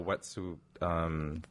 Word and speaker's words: Wet'suwet'en: Oh, the Wet'suwet'enitsu. – Yeah, there you go. Wet'suwet'en.